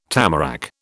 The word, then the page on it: tamarack: British English